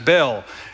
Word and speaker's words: Baal: Now, my old seminary professors would be a little bit frustrated with me for pronouncing Baal's name as Baal. His name is really more pronounced something more like Baal.